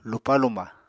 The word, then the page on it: lupá-luma: Apatani